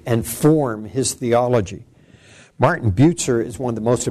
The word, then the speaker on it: Bucer: It was in Strasbourg that he met a man by the name of Martin Bucer, and this is pronounced Bucer rather than Bucer. So Bucer, it's like it's B-U-T-Z-E-R.